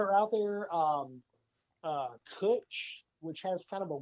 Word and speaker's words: Cwtch: It looks like Sea Witch but it's uh, spelled Cwtch, which is uh, Gaelic for hug.